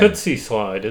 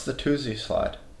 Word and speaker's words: Toosie Slide: It's The Tootsie Slide, isn't it? – No, it's The Toosie Slide. – Really? Even though it's spelled Tootsie? – It's not spelled Tootsie.